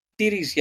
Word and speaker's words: Ceres: Space Facts this week looks at Ceres. I'm saying that right, aren't I? – Ceres, yeah, not Ceres or Karen or whatever.